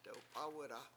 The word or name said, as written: dewp7Aw1da